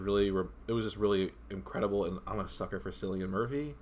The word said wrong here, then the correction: Cillian Murphy: It's Cillian Murphy. Thank you very Cillian. – Much. – I always say it wrong.